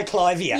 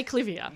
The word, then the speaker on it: Clivia: You've got to say Clivia. – Yeah, I still say Clivia. – Yeah. – You're the honest Clive of India, so it has to be Clivia.